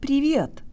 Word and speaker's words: привет: guys, today you'll learn how to pronounce the word privyet, or hi. When we begin studying Russian, our privyet usually sounds like privyet, but we can make it sound Russian if we do several things.